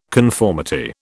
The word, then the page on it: conformity: British English